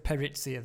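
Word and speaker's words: PERITIA: Paritsia or Parisia? – I think that's excellent. I've heard a lot of different pronunciations. I think this one is what works best. – No. Okay, well, let's go with Paritsia then.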